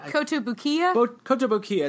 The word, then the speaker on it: Kotobukiya: Kotobukiya.